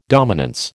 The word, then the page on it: dominance: American English